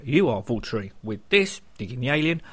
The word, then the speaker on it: Waltari: Apparently, for the last 21 years, I've been pronouncing the next band's name wrong. Not my fault, as the squirrel is the one who introduced me to the band in question, who are Waltari and not Waltari.